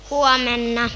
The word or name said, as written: huomenna